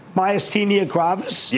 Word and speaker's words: Myasthenia gravis: Myasthenia gravis? – Yes.